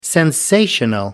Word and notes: sensational: American English

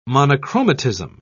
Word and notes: monochromatism: British English

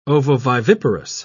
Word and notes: ovoviviparous: British English

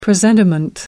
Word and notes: presentiment: American English

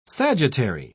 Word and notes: sagittary: British English

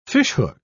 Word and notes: fishhook: British English

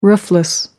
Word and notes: roofless: American English

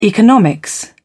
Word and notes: economics: British English

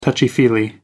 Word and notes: touchy-feely: American English

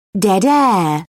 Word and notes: dead air: British English

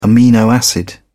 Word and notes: amino acid: British English

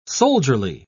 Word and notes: soldierly: British English

